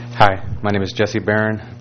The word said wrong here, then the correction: Barone: Barone. Mispronounced my own name. Sorry about that, Barone.